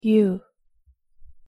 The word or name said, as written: ㅠ